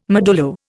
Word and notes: modulo: Indian

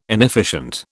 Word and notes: inefficient: British English

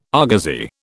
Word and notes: argosy: British English

